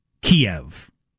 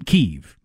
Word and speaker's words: Kyiv: Russians call it Kiev. The country of Russia, believing Ukraine belongs to us. Ukrainians call it Kyiv. So if the Ukrainians pronounce it Kyiv and only the invading army that is willing to kill them to subjugate them calls it Kiev, I'm going with Kyiv.